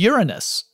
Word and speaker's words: Uranus: And he's the guy who discovered Uranus, the planet. And yes, I'm going to pronounce it that way, because to do it the other way makes it sound juvenile.